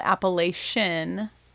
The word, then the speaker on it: Appalachian: And yes, the correct way to say this is Appalachian. It is not Appalachian. It is not Appalachian. It's Appalachian.